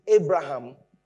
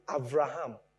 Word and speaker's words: Avraham: So there, you don't pronounce it Abraham. You pronounce it Avraham. So there, it sounds like a V, not a B.